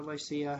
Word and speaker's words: Lycia: And when we had sailed over the sea of Cilicia and Pamphylia, we came to Myra, a city of Lycia, or Lycia, however you want to pronounce it.